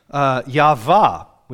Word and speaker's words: Yahweh: I had a Hebrew professor at Liberty University who was very insistent that it's pronounced Yahvah with a V, which when he said it quickly sounded like an F, Yahvah.